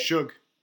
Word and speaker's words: Suge: It's called Suge. I think, I don't know how to pronounce it. – Shug. – Yeah, exactly. S-U-G-E.